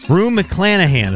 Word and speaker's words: Rue McClanahan: Rue McClanahan.